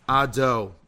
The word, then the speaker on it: Adonai: Hashem, which is spelled with the Yud, a Hey, and a Vav, Hey, which we pronounce Adonai, we say is Elokeinu.